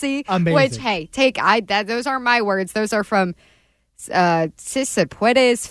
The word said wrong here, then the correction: Cespedes: Family Barbecue. – Joanna Cespedes Family Barbecue. – My bad. I was trying pronunciation live on air there.